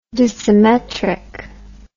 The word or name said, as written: dissymmetrically